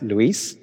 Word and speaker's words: Luis: So my name's actually pronounced Luis, but I've been called worse in the past, so I'm happy for you to call me Lewis. And all my mates call me Louis, so does my mother.